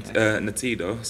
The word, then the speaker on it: Ntiedo: Ntido. So it's spelled N-T-I-E-D-O.